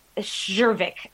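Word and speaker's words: Czerwiec: Zervic, I'm going to say. I'm going to spell that for you. It's C-Z-E-R-W-I-E-C.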